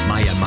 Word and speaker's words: Maya: And Maya has messaged me to say, Maya brackets rhymes with fire. Fire. Maya, Maya.